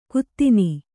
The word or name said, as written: ಕುತ್ತಿನಿ